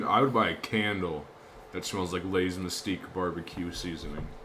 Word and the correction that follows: mesquite: You mispronounced mesquite. – What did I say? – You said mystique.